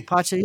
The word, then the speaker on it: Pacha: Pacha? – Sounds good to me. – Pacha, yeah, I just watched it too.